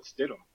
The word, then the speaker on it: Stidham: Stidham.